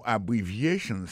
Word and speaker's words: abbreviations: En parlant de accent, accent tonique qui j'ai pour nous prononcer mot yo, n'a pas un mot abbreviations nan, qui signifie abréviation. Abbreviations, accent tonique là, tombé sous troisième syllabe là.